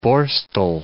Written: Borstal

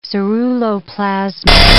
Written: ceruloplasmin

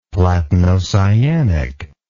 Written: platinocyanic

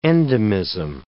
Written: endemism

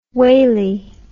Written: waly

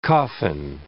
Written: coffin

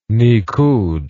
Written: nikkud

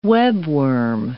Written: webworm

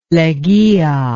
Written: Leguia

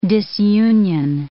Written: disunion

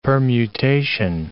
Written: permutation

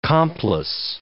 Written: complice

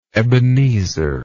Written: Ebenezer

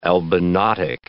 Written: albinotic

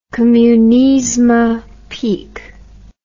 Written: Kommunizma Peak

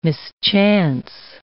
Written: mischance

